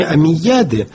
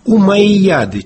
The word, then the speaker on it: Умайяды: У востоковедов принято именовать эту несчастную династию не Омейяды, а ближе к оригиналу, но по-русски совсем уж непроизносимо «Умайяды» через два и кратких.